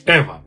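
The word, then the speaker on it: Eva: У них все серьезно, все прекрасно, но тем не менее Ева с самого начала... А, кстати, небольшой оф топ Я ее назвал Ева, правильно говорить Эва.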